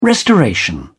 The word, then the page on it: restoration: British English